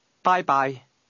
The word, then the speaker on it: 拜拜: Did you hear the high and mid-level tones in that word? Say it again. – Bye-bye.